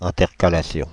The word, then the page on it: intercalation: [ɛ̃.tɛʁ.ka.la.sjɔ̃]; Paris; France (Île-de-France)